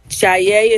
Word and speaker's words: Jaye: Jaye is spelled J-A-Y-E. And my last name is M-C, capital N-A-I-R, Obaseki, O-B-A-S-E-K-I.